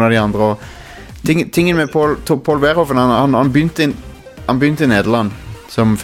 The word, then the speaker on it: Verhoeven: Paul Verhoeven, som jeg tror jeg er ikke helt sikker på om jeg uttaler navnet hans riktig, men det er sånn jeg uttaler det i hvert fall. – Jeg tror det er korrekt.